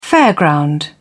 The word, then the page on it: fairground: British English